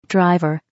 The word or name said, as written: driver